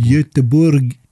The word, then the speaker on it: Göteborg: Goetheburg. – Göteborg.